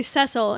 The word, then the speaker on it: Cecil: And I've been calling him Cecil Beaton, but it's actually Cecil. And I suppose we should respect how he pronounces his own name. So I guess I'll move forward with that, even though American pronunciation does not allow us to do that without writing it phonetically. So I did. So everywhere in here, I wrote it S-E-S-S-U-L.